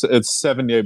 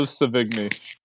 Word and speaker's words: Sevigny: Yeah, Chloe Sevigny in Boys Don't Cry is awesome. – No, you got to pronounce it like an American. There is no, it's Sevigny Blanc. it's Chloe Sevigny.